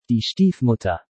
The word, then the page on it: Die Stiefmutter: German